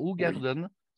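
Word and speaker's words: Hoegaarden: Hougarden, c'est aussi une localité flamande. Ça s'écrit bizarrement. Hein. Ça s'écrit avec H-O-E, parce qu'en néerlandais, en flamand, O-E se prononce OU, comme le O-U français. Donc, oui.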